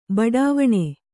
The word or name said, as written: ಬಡಾವಣೆ